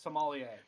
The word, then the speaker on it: sommelier: And at the time, you used the term, I hope I'm saying it, Somalia? – I can pronounce it correctly for you. It's Sommelier.